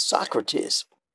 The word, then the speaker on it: Socrates: But I just wanted to share with you that the way that we pronounce that word is Socrates.